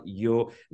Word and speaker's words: Yoo: Of course, his name is Scott Yo. Let me spell it for you. S-C-O-T-T. Yo is spelled Y-O-O.